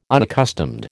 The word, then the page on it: unaccustomed: American English